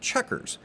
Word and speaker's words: Chequers: A little interesting story behind Checkers. It's spelled C-H-E-Q-U-E-R-S, Checkers with a Q.